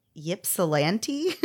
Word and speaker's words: Ypsilanti: Okay, so it is spelled Y-P-S-I-L-A-N-T-I. I'm going to say, I don't even know. Ypsilanti? – You know what? Take off that Y and you got it. It's Ypsilanti.